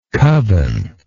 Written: coven

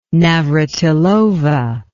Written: navratilova

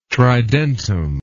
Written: tridentum

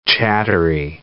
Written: chattery

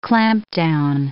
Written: clampdown

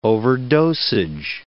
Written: overdosage

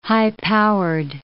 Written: high-powered

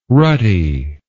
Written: rutty

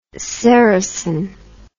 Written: ceresin

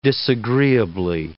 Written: disagreeably

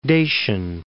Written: Dacian

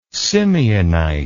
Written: simeonite

